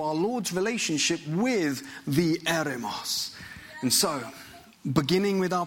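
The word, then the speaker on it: eremos (ἔρημος): Now, the word for wilderness in the Greek, it is the term eremos. Can you say eremos? eremos.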